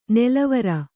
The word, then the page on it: ನಲವರಿ: nalavari